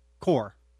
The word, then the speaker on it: coir: There's one thing that you told me about, and it's called coir. – Core. – Core. – C-O-I-R. – I've seen it pronounced both ways. – Okay. – Coir, core, but it's C-O-I-R.